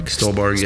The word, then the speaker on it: Stuhlbarg: Stuhlbarg, yeah.